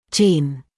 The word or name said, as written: gene